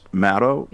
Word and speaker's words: Maddow: The one thing I would want to do for him is just note, she doesn't really pronounce the W. It's Maddow. – Exactly. – Not the way he says it. Right. So just kind of drop the W off when you're saying it, and you'll be more in the strike zone.